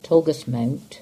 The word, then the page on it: Tolgus Mount: English